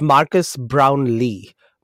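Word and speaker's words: Marques Brownlee: I don't know if you've heard of Marcus Brownie. I think I said his name right. Maybe I didn't. MKBHD. He's like the biggest YouTuber ever. So it's Marcus Brownlee. Brownlee.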